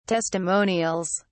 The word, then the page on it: testimonials: British English